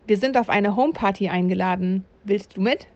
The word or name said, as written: Homeparty